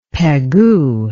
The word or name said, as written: Pegu